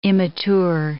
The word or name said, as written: immature